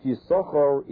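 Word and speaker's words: Yissachar: Now we know the name Yisachar is written with two shins, and Lamaisa we only pronounce one. One is written, and we do not pronounce it. We call it Yisachar, even though the Torah is written Yisachar.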